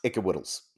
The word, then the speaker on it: IKWYDLS: Yes, I believe it's pronounced Ickawittles. So Ickawittles Extended Universe.